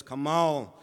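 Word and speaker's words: kamal (חמל): Now the word pity, put it up there, is the word kamal. And I'm not pronouncing that like a true Jew would, but it's kamal, and it means to spare.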